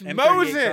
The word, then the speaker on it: Mosin: The Moisen, the Gant. – It's Mosin. – Carbide, so Mosin.